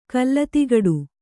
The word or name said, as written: ಕಲ್ಲತಿಗಡು